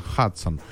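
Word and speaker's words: Hudson: Мы привыкли ее называть Гудзон, хотя все американцы во всех фильмах, во всех... В справочниках она, естественно, называется Хадсон, потому что и человека, который, в честь которого она названа, звали именно так, Хенри Хадсон.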